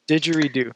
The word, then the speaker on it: didgeridoo: Didgeridoo.